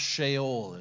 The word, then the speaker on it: Sheol: It's pronounced Sheol if you're ever reading through it.